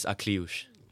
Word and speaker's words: Akliouche: Is it Aliouche the other guy? – Watch the pronunciation there. If there's two names I want you to remember in that Monaco squad, it's Elias Benzegui and Marnes Akliouche.